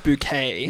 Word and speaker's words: Bucket: It's pronounced bouquet.